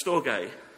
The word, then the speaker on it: storge: And there's another word, pronounced storge.